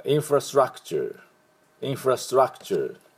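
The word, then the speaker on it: infrastructure: でそれで、インフラストラのところはあでストラクチャーでチャーの音ですね、チャー T-U-R-E をチャー、はい、体育会系の、学生が挨拶するときのチャーです。でインフラストラクチャーと発音します。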